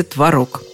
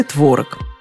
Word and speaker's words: творог: Хотите быть безупречно грамотным, говорите «творог». Но если это вас не слишком беспокоит, сойдет и «творог». Словари не считают этот вариант ошибкой. Такое ударение приводится в качестве варианта.